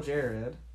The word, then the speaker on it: Jared: Is it Gerard? Gerard? I'm like, no, it's Jared. – How can they not just pronounce Jared? – I know, I feel like that's the easy way. Just like, oh, it's spelled weird, but it's still Jared.